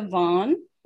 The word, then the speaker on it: Vaugn: Well, I am on all the major platforms, Jessica Vaughn, and Vaughn is spelled V-A-U-G-N.